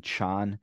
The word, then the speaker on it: Chan: actually, this is a school that originates in China, and the Chinese pronunciation in modern Chinese would be Chan.